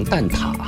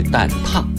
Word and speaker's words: 蛋挞: 蛋挞没有秘密，生活当中呢，很多朋友习惯性的读成蛋挞哈，但是它的准确读音呢，确实是蛋挞，因为一个提手旁，一个发达的达，这个字呢，只有挞这一个读音，是用鞭子或者棍子打的意思，啊，鞭挞就是这个字哈。